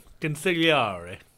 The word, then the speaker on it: consigliere: Is my saying that correctly? – Consigliere.